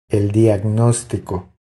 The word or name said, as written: El diagnostico